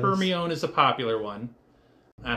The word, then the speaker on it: Hermione: But we finally, and this is the fabled line that told everybody how to actually pronounce her name. Hermione. Hermione.